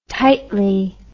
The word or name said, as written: tightly